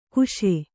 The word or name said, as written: ಕಷ